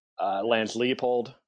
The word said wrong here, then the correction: Leipold: Leipold, and then the, the rest of the field, okay.